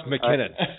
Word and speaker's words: McKinnon: It's pronounced McKinnon.